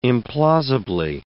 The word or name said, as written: implausibly